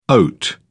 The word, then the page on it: oat: British English